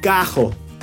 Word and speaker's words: carro: Por ejemplo, carro se pronuncia cajo.